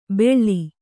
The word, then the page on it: ಬೆಳ್ಳಿ: beḷḷi